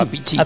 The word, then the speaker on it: Appice: Sí, y yo tampoco sabía que se pronunciaba Appice.